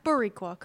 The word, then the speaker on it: Boricua: And that was Eric Rivera from Addo and the Boricua burger, which was a little more. – I'm not going to lie, this burger looked kind of bad. – That's Boricua.